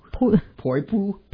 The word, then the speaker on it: Poipu: Hey, pronounce this one. P-O-I-P-U. Poipu? Poipu? – Poipu.